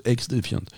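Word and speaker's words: XDefiant: Ouais, mais ça se prononce X défiante. C'est X défiante.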